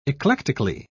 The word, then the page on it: eclectically: British English